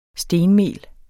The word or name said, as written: stenmel